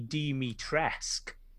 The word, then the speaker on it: Dimitrescu: And the U is silent, apparently. So it's Lady Dimitrescu. – Uh-huh. – So there isn't a U on the end of it.